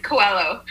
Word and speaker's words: Coelho: It's Coelho.